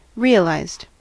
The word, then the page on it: realized: American English